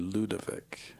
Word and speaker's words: Ludovic: Ludwig. – Yes, what did I say? Well, it's... – Well, you said it okay. I just... – Ludovic. It's actually Ludovic.